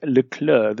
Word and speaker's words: Leclerc: His name is Leclerc. The C is silent, as in... No, I won't go there. – The C's are silent, so Leclerc.